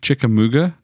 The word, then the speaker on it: Chickamauga: Well, there's no N. Chickamauga.